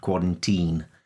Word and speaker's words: quarantine: Yeah, it's the long I that in a lot of languages just doesn't exist. So people are saying virus and isolation, whereas it's isolation and virus and quarantine, which doesn't have the long I. It has an E sound, quarantine.